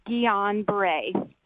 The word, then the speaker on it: Guillain-Barré: Actually, what you guys are talking about is pronounced Guillain-Barré.